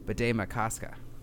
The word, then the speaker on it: Bde Maka Ska: Also, fun fact, I looked up last weekend how to pronounce the new name for Lake Calhoun, and it's Bede Makaska.